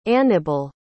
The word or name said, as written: anibal